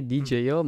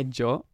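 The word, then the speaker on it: Djo: Carissimi, carissimi, mi devo scusare con voi per la pronuncia, perché non è DJ O, ma è Joe.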